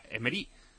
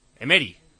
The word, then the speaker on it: Emery: Y dice otro que nos enrocamos mucho con el tema del nombre de Faisal o como se diga, pero Emery no lleva tilde y sería Emery. Emery.